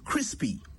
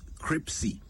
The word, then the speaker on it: crispy: Crispy, not cripsy. Try saying it slowly. Crispy. First, you say Chris. Crispy. That is how you say it right.